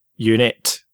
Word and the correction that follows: Unity: Unity. – Unity.